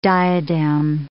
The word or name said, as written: diadem